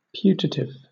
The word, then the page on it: putative: British English